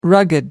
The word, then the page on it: rugged: American English